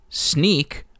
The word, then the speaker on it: Snyk: Snyk, or S-N-Y-K,